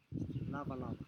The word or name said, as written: lavalava